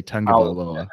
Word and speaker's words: Tagovailoa: And you know, for Tua to be able to sit back there, Tulia, how do you say his name? – You corrected me last week when I messed it up. So I'm going to just say Tagovailoa.